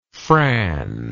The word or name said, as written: fran